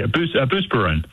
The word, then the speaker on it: Buspirone: I mean, she was on a boatload of Buspirone. Am I saying that correctly? Probably not. – Yeah, Buspirone.